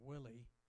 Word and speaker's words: Willie: My name is Ron Willie, two L's. One L, by the rules of English, would be pronounced Wiley, so in case you wondered.